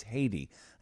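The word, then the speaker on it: Hedy: I've always pronounced it Hetty. I'm told today it's Haiti.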